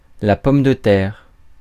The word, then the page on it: pomme de terre: France (Paris); [pɔm də tɛʁ]